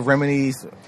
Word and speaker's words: Remini: I've seen some of Leah Remini's... Right? Yeah. Is that how you pronounce her last name? – Yeah, Leah Remini has a special...